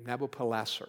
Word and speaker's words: Nabopolassar: His father was Nabopolassar is how you say that name, Nabopolassar.